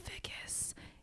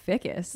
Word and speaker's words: Vickis: Alana Vickis is my name, and also my last name's never pronounced right, which does not bother me. But you probably heard that, and you were like, Vickis. That's how it goes.